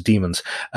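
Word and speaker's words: Dæmons: I was actually thinking of watching I pronounce it Daemons, Daemons, mm-hmm. but some people pronounce it Demons.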